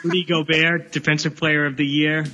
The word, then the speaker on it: Gobert: Defensive Player of the Year was Rudy Gobert. Is that how you say it? I think Utah. – Rudy Gobert.